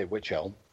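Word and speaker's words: Wych Elm: This is called Bella in the Witch Elm. WYCH, is that how you say it in UK? – I would say Witch Elm.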